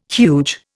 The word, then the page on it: huge: Indian